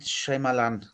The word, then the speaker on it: Shyamalan: Äh, und ich habe auch den neuen von, von ihm jetzt hier, ich kann den Namen niemals aussprechen. – Schau Nein, an. Shyamalan.